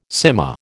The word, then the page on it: cyma: American English